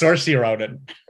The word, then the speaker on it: Saoirse Ronan: Brooklyn was Saoirse Ronan. It's a different movie. – Not the god damn it. – Good call that. Also, you said it correctly, that's Saoirse Ronan.